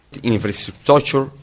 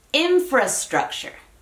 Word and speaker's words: infrastructure: Es una palabra que se traduce directamente al español, infraestructura, infrastructure. Entonces, aunque comparte el fra, en inglés es fra, infrastructure. La U que les mencionaba está en la sílaba de structure.